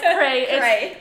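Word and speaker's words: Crëu: We all say crew. – Yeah, it's Cray. It's a Welsh word, so I'll let you all off.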